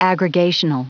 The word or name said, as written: aggregational